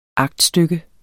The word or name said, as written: aktstykke